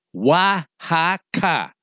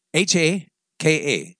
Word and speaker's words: Oaxaca: So here it be phonetically. Oaxaca. Oaxaca. All three syllables sound the same. Oaxaca.